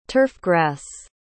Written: turfgrass

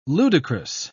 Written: ludicrous

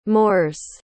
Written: moors

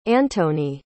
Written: antonie

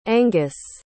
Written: angus

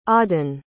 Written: auden

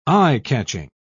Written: eye-catching

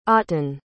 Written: otón

